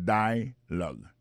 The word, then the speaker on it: dialogue: Alors, mot dialogue, ça c'est dialogue. Ça nous a remarqué là, nous a remarqué que l'accent tonique là tombe sous premier syllabe là.